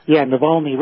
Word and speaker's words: Navalny: Alexei Navalny, if I'm pronouncing that correctly. – Navalny, right.